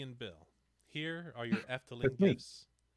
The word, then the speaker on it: Efteling: Efteling.